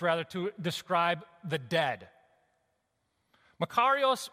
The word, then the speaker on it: makarios: It's a word it's pronounced makarios, it's a word that means divine joy and supreme and utter and perfect happiness and satisfaction.